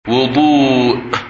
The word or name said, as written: Wudu